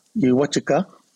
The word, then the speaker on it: Uwatchika: Uwatchika is how you say it in Southern Ute.